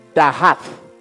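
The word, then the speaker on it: dahath (דעת): But using English letters, you would pronounce it as dahath something like that.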